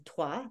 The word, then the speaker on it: Troyes: Trois, this which is spelled Troyes,